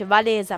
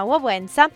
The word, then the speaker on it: Wałęsa: A guidare la protesta c'è un uomo che avrebbe poi rappresentato, insomma, il futuro della Polonia, che è Lech Wałęsa, che si pronuncia così, l'abbiamo scoperto da poco, che non si dice Walesa ma Wałęsa.